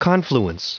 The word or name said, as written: confluence